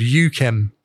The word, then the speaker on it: UKHEM: It's called UKHEM, and it's spelled UKHEM. And the point is that it's UK because it was made in the UK, and then the sound was chem. So it was UKHEM, and it was basically an OG Kush S1.